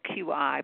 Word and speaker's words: Qi: And let me even clear up the word Qigong because people see it's spelled Q-I, but it's not pronounced Q-I. It's pronounced just like Qi.